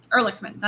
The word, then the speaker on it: Ehrlichman: Ehrlichman, that's how you pronounce it.